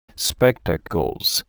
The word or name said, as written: spectacles